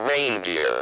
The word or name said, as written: reindeer